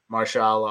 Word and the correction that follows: Mahershala: I always know if I'm mispronouncing his first name. – Mahershala. – Mahershala Ali.